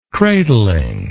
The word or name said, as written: cradling